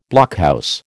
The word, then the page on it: blockhouse: American English